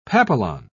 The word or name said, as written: papillon